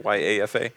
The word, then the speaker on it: YAFA: YAFA, do you ever pronounce it YAFA as an acronym, YAFA? – Yes, it is, and this is a society-wide initiative to implore not just youth involvement, but as it says, youth and family involvement.